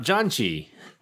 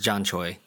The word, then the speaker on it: janchi: But first, we're talking about janchi, which I will clarify again is spelled J-A-N-C-H-I.